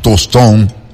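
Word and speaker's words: Tostão: Pelé, Rivelinho, Tostón, ¿eh? Qué buen acento, ¿no? Se escribe Tostao, pero dices Tostón, ¿no?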